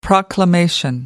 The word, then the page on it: proclamation: American English